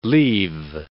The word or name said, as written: leave